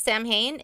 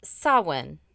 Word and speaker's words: Samhain: It's actually pronounced Samhain. – Oh, so I did it wrong. – But you know what? I would have pronounced Samhain. It's S-A-M-H-A-I-N. Samhain. – Yeah. – No, it's pronounced Samhain.